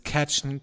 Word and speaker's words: Cajun: Bietet Spezialitäten des Cajun Country.